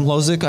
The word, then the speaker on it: Hložek: Um, it didn't go so well, but I think um, one of my favorite players, as you mentioned, Schick versus um, Adam Hložek. I mm-hmm. apologize for the pronunciation. Oh, that's actually bang on.